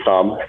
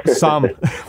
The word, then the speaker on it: Sahm: You know, in your notes, you mentioned the SAHM recession rule, or SAHM, is it? S-A-H-M? I'm not sure how you pronounce it, but I believe it's SAHM. – SAHM. We'll go with SAHM.